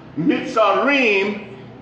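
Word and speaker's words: Mitzarim (מצרים): Mitzarim is how it's pronounced in Hebrew.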